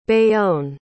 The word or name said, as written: bayonne